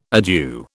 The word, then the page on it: adieu: British English